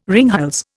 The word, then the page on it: ringhals: Indian